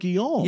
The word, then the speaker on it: Guillaume: What's his, Robert, how do you say his name? Guillaume? – Robert Guillaume.